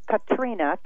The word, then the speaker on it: Katrina: And then um, actually rhymes with Bettina. It's Katrina, K A T R I N A, who's the angel of prosperity.